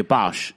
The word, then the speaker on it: Boush: Bosh? How do you say that? Because you don't say Bush. B-O-U-S-H. – B-O-U-S-H. Yeah, Bosh.